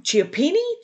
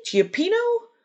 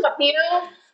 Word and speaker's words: cioppino: Have you ever made that Italian, I'm going to say it wrong, cioppini? Cioppino? The fish stew? – Cioppino?